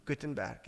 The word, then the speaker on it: Gutenberg: Gutenberg, yes.